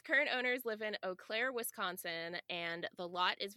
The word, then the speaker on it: Eau Claire: Its current owners live in, I'm going to probably butcher this, Eau Claire? Eau Claire? – Eau Claire, yeah.